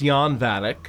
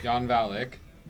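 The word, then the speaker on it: Jan Valek: with Jan Valak, Thomas Ian Griffith. – It's pronounced Jan Valak. – Jan Valak. Thomas Ian Griffith, a vampire kingpin possessed of incredible powers. – Yeah, that sounds good.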